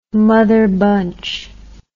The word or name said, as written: mother bunch